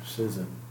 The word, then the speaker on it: schism: S-C-H-I-S-M, schism, or schism, somebody might pronounce it that way.